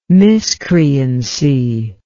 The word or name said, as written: miscreancy